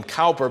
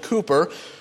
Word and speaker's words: Cowper: Now, William Cooper, some say William Cowper, but it's pronounced William Cooper.